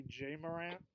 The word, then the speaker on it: Ja Morant: I keep calling him Ja Morant, but it's really Jay Morant. Without a Y.